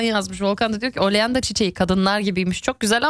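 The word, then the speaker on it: Oleander: Almancası nedir demiştim. Tam telaffuzunu düzeltin lütfen demiştim. Gorca yazmış, diyor ki oleanda diye okunuyor dedi.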